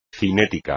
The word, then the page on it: cinético: espagnol